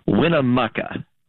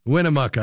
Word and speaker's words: Winnemucca: I guess it's Winnemucca, but I'm probably saying that wrong. Thank you for calling. How do you say your town? – Winnemucca. – Winnemucca. I was close.